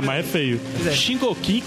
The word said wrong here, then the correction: Shingeki: como é que é? – Shingeki no Kyojin.